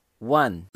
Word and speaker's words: one: Então não é one nem one, é one. O E é no final não é pronunciado, como a maioria das palavras em inglês, quando tem terminadas em E, é, você não pronuncia esse E. É. One. Você termina com a língua no céu da boca.